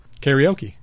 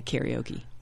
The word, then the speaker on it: karaoke: One, of course, is the fact that the American pronunciation of this word is kind of goofy. – Yeah, it's wrong. – Karaoke. – Yeah, karaoke. – Yeah. – That's silly. – The correct pronunciation is... – karaoke.